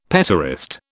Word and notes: petaurist: British English

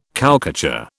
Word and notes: cowcatcher: British English

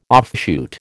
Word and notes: offshoot: American English

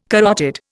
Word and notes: carotid: Indian

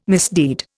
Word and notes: misdeed: Indian

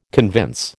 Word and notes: convince: American English

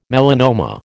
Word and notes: melanoma: American English

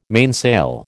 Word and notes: mainsail: American English

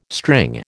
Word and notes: string: American English

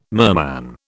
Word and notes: merman: British English

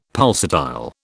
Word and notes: pulsatile: British English

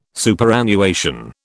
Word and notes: Superannuation: British English

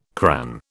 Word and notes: cran: British English

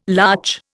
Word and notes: larch: Indian

